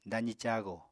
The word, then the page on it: dazi cago: apatani